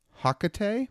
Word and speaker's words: Hakotei: He was accompanying Sifo Dyas and Leen Kostana to a planet that I've never heard pronounced before, Hakotei. H-A-K-O-T-E-I? Hakotei? – Hakotei. Sounds about right.